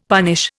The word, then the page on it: punish: Indian